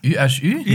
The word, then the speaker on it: UHU: Ü-Ü? – Ja, Ü. Weil Franzosen sprechen ja H nicht aus, sonst mhm. würde Uhu heißen Ü.